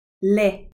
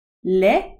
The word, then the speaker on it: lait: Riz, lait is spelled L a I T.